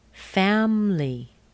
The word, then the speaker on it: family: And the next word is family. Family. Does that surprise you? There's no I there. There's no I. Family. Family.